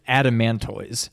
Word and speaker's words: Adamantoise: Adamantinous? – Close. You got an extra syllable in there. It's Adamantoise.